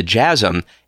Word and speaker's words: teas: However, the similarity in meaning of the earliest jazz citations to jazzum, a now obsolete slang term meaning spirit, energy, vigor, dated back to 1860. Many credible sources have contested it derived from Irish teas, which is pronounced jazz, J-A-S-S, and means heat or passion.